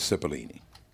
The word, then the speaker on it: Cipollini: Actually, you pronounced it, if we were in Italy, apparently it would be Cipollini. – Right. – Here, everybody, they turn into an S. – So it is Cipollini.